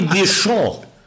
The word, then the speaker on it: Deschamps: Didier Deschamps. – Ah, wirklich nur Deschamps? – Ja, der Baske.